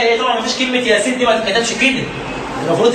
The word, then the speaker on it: ياسين: ياسين والقران. وعايز يقول لك يا سين دي بتتنطق كده، او سين ساكنه، هو النون الساكنه في الواو ادغام النون الساكنه في الواو بتاعت ياسين والقران.